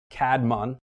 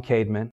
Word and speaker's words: Caedmon: His name is Cadman. It's actually pronounced Cadmon, but we call him Cadman.